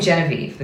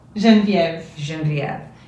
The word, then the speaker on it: Genevieve: I want to say Genevieve, but that's not right. Genevieve. – Perfect.